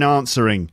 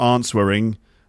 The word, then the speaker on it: answering: It's got a silent letter in it. Where's the silent letter in answering? Of course, it's the W. So it's not answering. No, answering. Yeah, I could normally say that word fine, but, you know, stuff happens sometimes, doesn't it?